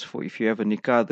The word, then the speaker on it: Nikah: And it's Nikah, Noon, Kaf, Alif, Ha, Nikah. So as Mufti Saab says, people spell it Nikah. So it's not Nikah, it's Nikah. And that will be our program today.